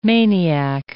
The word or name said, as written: maniac